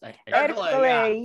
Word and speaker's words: Ercole: And then um eh, and then uh Ercole comes in or is that how it's pronounced? – Ercole, yes. Um,